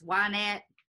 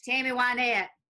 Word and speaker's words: Wynette: Johnny Cash and Tammy Wynette actually did a duet remake of that song. – You're pronouncing that name wrong. It's Wynette. – Tammy Wynette. – It's Tammy Wynette.